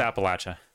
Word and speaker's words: Appalachia: Oh, see, that's you were saying it how I was saying it. – Yeah, it's easy to say it wrong sometimes because maybe you're saying it right. – No, I'm not. It's Appalachia, literally.